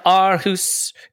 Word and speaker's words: Aarhus: I do want to mention Jack Wilshere going to Danish side Aarhus. Is that, do we think that's how we pronounce that? – Aarhus in the middle of the street.